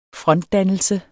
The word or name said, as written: frontdannelse